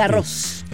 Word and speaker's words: Garros: Y no, y en el francés la S final se pronuncia. Y claramente, Garros.